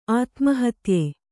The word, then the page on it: ಆತ್ಮಹತ್ಯೆ: ātmahatye; kannada